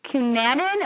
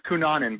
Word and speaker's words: Cunanan: Can you tell us about My Night with Andrew Cunanan? Am I saying that right? – Yeah, it's Cunanan, but close enough.